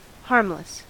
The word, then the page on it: harmless: American English